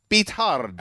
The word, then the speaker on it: Beathard: It's Beathard. You didn't know that's how you actually pronounce it? – I didn't know that.